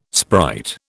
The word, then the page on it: sprite: British English